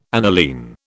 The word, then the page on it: aniline: British English